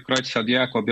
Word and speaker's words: Creutzfeldt-Jakob: É essa mesma a pronúncia? Pra gente... – É, Creutzfeldt-Jakob, tanto faz.